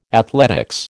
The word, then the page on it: athletics: American English